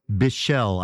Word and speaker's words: Bichel: Let's see, Donald Harrison played soprano sax, John Patitucci on electric bass, Dave Weckl on drums, Ken Bichel, I believe is how it's pronounced, B-I-C-H-E-L, heard on synthesizer,